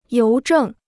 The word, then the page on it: 邮政: yóu zhèng